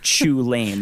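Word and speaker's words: Chulane: He's going to talk about Chew Lane. That's how it's pronounced, yeah.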